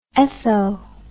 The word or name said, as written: etho